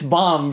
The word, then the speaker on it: bomb: No matter what accent you want to speak in, it's bomb, bro. Don't pronounce the B, please. I have a friend...